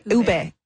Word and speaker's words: ube: Ube? – Ube.